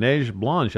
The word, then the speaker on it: Neige Blanche: And you were right on with the pronunciation. Neige Blanche actually went back and watched one of her races and listened to the call, albeit in French, and that was correct.